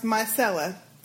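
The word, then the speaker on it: Myrcella: I was rooting Sistah J on with Myr, with my, M-Y-R is pronounced Mycella.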